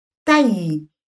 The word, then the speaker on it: Tai: Se vocaliza en una sola exhalación todo el mantra, la sílaba Tai en un tono más fuerte, acentuando la letra A de esta forma, Tai, haciéndolo en un tono profundo, y el re re re en un tono más alto que Tai.